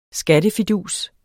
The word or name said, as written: skattefidus